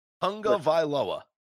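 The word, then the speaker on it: Tagovailoa: Bottom line, Tugalua, to your Tugalua and my Tugalua, Tugalua, however the fuck you pronounce that guy's name. – Tunga Vailoa.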